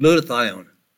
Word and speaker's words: glutathione: Now I remember when I opened up the program, I suggested we would talk about glutathione. It's spelled G-L-U-T, glut. Thione, T-H-I-O-N-E. G-L-U-T-A-T-H-I-O-N-E.